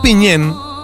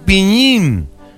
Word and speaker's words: piñén: La expresión piñén a menudo se pronuncia coloquialmente como piñín.